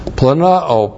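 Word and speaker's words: planao (πλανάω): Planao, P-L-A-N-A long O, planao.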